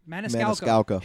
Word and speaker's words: Maniscalco: Maniscalco.